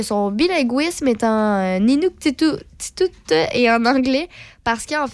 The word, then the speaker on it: Inuktitut: Inuktitut? Inuktitut. Ouais. Je pense qu'elle se prononce comme ça. Exactement.